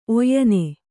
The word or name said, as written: ಒಯ್ನ